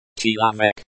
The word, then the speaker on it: человек: The word chilamek, pronounced chilamek, means person or man.